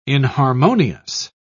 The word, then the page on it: inharmonious: British English